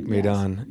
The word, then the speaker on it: Maidan: Am I saying that right? – That is correct. Maidan. Yes.